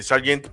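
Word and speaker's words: Sargeant: En el caso de Logan Sergate, eh, eh, Sargent,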